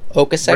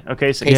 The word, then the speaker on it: Ocasek: Rick Ocasek? Is that his name? – Ocasek. – Rick Ocasek. Yeah. Ocasek.